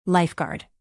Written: lifeguard

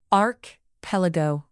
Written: archipelago

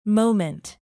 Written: moment

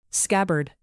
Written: scabbard

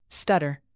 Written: stutter

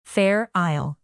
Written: fair isle

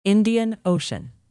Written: indian ocean

